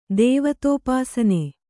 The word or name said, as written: ದೇವತೋಪಾಸನೆ